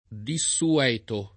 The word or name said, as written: desueto